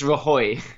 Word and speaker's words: Rajoy: The head of the Catalonian government has said he's going to declare independence, and now the Spanish Prime Minister Mariano Rajoy has said that he's thinking about suspending Catalonia's autonomy. – Sorry, I laughed at your pronunciation. – Yeah, it's Rajoy.